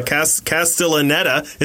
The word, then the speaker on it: Castellaneta: Is that how you pronounce his name? Yep. Like Castellaneta is in it for, he has like three lines.